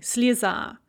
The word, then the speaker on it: слеза: So we have the word слеза, a tear, and in the nominative singular, the stress is on the A, and so that yeah there is pronounced as an E, слеза.